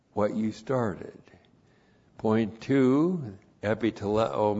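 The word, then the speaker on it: Epi teleo (ἐπιτελέω): Epi teleo. E-P-I-T-E-L-E long O. Epi teleo.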